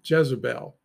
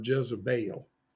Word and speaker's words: Jezebel: That's Jezebel or Jezebel, if you want to get the correct pronunciation there.